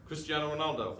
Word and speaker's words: Cristiano Ronaldo: A worst case scenario, Portuguese footballer Cristiano Ronaldo, Is that how you say his name? – Cristiano Ronaldo. – No H in there, hey? – No, it's Portuguese.